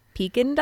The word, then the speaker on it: Pekin: So we're hatching Pekin ducks.